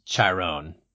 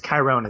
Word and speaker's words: Chiron: I think this is how you pronounce his name. Is it Chiron? – I don't think he's a Greek mythological figure. I think it's Chiron. Is how it's pronounced in the film.